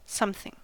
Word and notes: something: American English

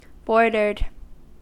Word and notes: bordered: American English

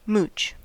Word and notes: mooch: American English

